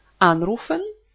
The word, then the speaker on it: anrufen: Приставка «an» – это приставка отделяемая, потому что на нее падает ударение «anrufen».